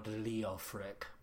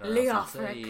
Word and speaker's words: Leofric: Like, they have, like, a whole backstory behind her is that her husband, Lord Leofric, which you have to say it like that. – Leofric. – Yeah, you have to say it like that or else it's not worth it.